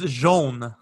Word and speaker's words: Jean: Did I pronounce that correctly? – It's Jean.